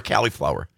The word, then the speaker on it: cauliflower: Cauliflower or cauliflower? – Cauliflower. Like C-U-L, the dog. Collie.